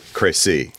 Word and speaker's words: Crécy: Now, I use the English pronunciation, but Michael, you would say something else. – I would say Cressy, yeah. It's a word that there's sort of the technical French way of saying it.